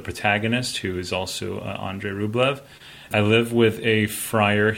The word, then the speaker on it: Rublev: And so he was getting on my case for how badly I was pronouncing Andrei Rublev.